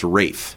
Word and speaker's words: Ralph: Now, I have one question about this, because I've heard his name pronounced Rafe. – Yes, I've heard that, too. It's the old school.